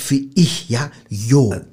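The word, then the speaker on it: yo: Also, Joe ist nicht Joe, das erst einmal, ja.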